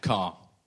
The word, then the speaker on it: Kerr: It's spelt like the Kerr, but it's pronounced like the vehicle.